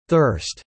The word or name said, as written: thirst